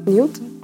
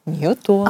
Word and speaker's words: Ньютон: Закон всемирного тяготения открыл Исаак Ньютон. А. – Ньютон, а Ньютон, да? Ну там типа... Ну, Извините, я все равно засчитаю, хоть и ударение было не туда.